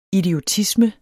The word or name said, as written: idiotisme